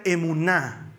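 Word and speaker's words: emuná (אמונה): La fe en hebreo se pronuncia emuná.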